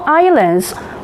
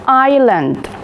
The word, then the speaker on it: island: One, the USA has many small islands or big cities. Number two, Canada has deserts or waterfalls. Now, boys and girls, pay attention to this word. Island. Island. Letter S has no pronunciation in this word.